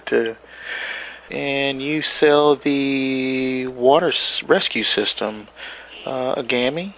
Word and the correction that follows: Agama: Am I saying that right? I'm sorry. – The Agama, yes.